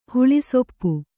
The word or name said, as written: ಹುಳಿಸೊಪ್ಪು